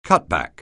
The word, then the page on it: cutback: anglais